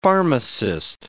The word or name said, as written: pharmacist